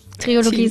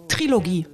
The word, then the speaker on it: Trilogie: Ja, und dann, die haben es beide richtig gesagt. Es heißt Trilogie.